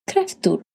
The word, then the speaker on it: kräftor: And kräfta, one crayfish, flera eller många kräftor. Many crayfish. Kräfta. Kräftor. Kräfta. Singular. Kräftor. Plural. So it's spelled K-R-A with the 2 dots on the top.